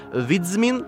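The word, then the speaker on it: Wiedźmin: По-польски это читается примерно как «Видзмин», простите мой польский.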